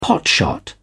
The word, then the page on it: potshot: British English